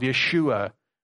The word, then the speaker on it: Yeshua: Joshua, you'll see in our text from Ezra, Jeshua is the way that it's spelled. Yeshua would have been his name.